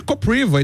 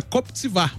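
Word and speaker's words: Kopřiva: Porque, por exemplo, tem um tenista que é o Koptsvar, mas se escreve Kopriva. Então todo mundo chamava ele de Kopriva e tal. Aí ele não, é Koptsvar, porque em tcheco, né, se fala assim.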